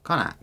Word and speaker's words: Connacht: Connacht. – Connacht. – C-O-N-N-A-C-H-T.